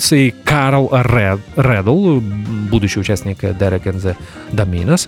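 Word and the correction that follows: Dominos: Извините, Доминос, часто ошибаюсь. Часто делаю неправильное ударение в этом слове.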